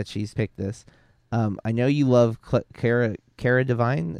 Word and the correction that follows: Delevingne: Is that how you say her name? Cara Devine? – Delevingne. – Yeah, Delevingne. There it is.